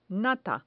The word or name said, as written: nata